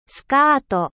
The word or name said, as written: jupe